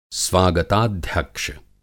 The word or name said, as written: स्वागताध्यक्ष